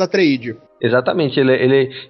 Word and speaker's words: Atreides: É, o protagonista do livro é o, o Paul, Paul Atreides, ou Paul Atreides, qual seria a pronúncia?